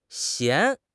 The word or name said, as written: 弦